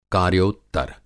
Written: कार्योत्तर

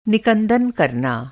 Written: निकंदन

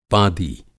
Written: पादी

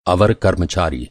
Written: अवर

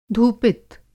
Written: धूपित

